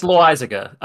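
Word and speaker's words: Loaisiga: I mean, it's Loizaga. I know how to pronounce it, but New Yorkers are not going to say that.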